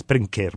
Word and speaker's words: Brynkir: it's Brynkir, but B-R-Y-N-K-I-R.